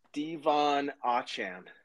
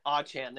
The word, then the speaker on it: Achane: Devon Achane. – Achane, there we go.